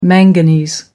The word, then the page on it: manganese: American English